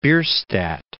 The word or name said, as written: Bierstadt